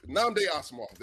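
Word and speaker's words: Nnamdi Asomugha: Namdi Asamoah. There you go. Namdi Asamoah is a gotcha.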